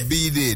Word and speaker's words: bidet: Is it a somebody saying a bidet? – Bidet. – B-Day is B-Day? – A B-Day, that's correct.